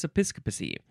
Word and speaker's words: episcopacy: Now, I would want to say episcopacy, but no, it's episcopacy. E-P-I-S-C-O-P-A-C-Y.